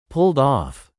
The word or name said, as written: pulled off